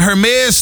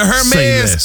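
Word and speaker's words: Hermès: Savannah James was on a podcast the other day, and she was speaking about her experience at a Hermes store in Italy. Is that how you see? I always hit the H. – H is silent.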